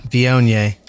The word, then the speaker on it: Viognier: So the Chenin Blanc and the Viognier, if I said it right, Viognier.